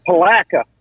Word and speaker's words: Palatka: You pronounce it Palatka.